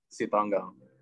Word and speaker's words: Sitanggang: So, basically, I've had so many people mispronounce my last name. So for every one of you listening, how you pronounce my name is Jonathan Sitanggang.